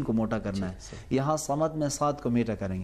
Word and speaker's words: صمد: اسی لیے تھوڑا سا مشکل نہیں ہے، دیکھیں اللہ الصمد کے بجائے میں نعوذ باللہ اللہ الصمد، صمد صمد، منہ میں ہوا تھوڑی سی بھر جائے گی تو یہ حرف.